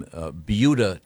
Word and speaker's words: Buda: we have yet to get to sports, but we were discussing a very interesting news item from Beuda, Texas. – Mm-hmm. – Insist on saying. – No, that's how it's pronounced. – Okay. – Is it B-U-D-A?